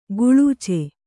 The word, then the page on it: ಕೊಳಚೆ: koḷace